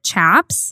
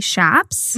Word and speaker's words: chaps: So I've always pronounced it Chaps. – Mm-hmm. – But she ended up saying that it's actually Shaps, mm-hmm. – which I think... It sounds like there's two different ways to say it. We did a little bit more research into it. – Yeah. – But just, it sounds like in the Southwest region of the U.S., they pronounce it Shaps, mm-hmm.